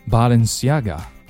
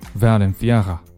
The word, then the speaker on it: Balenciaga: Balenciaga Balenciaga 当然你也可以按照西班牙语的方式去读 Valenciaga